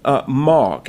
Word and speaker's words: Mauck: And so right now, I've got, through my connection with Dr. Dobson, Kenny Mauck. Am I pronouncing that correctly? – Like a mockingbird. You're right. – M-A-U-C-K.